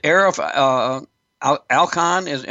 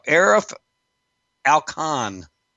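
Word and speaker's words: Arif Alikhan: Is it Arif Alkhan? Am I pronouncing that right? The Department of Homeland Security. Excuse me. – The preferred person who's currently there. – Arif Alkhan, I believe it's how it's pronounced.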